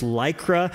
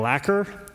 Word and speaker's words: Lycra: dirty South and couldn't pronounce Lycra, and so kept saying lacquer, lacquer, lacquer.